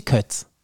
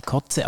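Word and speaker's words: Koze: Ist das DJ Kotze oder DJ Kötz? Nein, Kotze, aber er meint ja nicht Kotze zu sagen. Oder nennt er sich Cozy? Maxi DJ Cozy. – Kotze.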